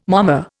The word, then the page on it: momma: Indian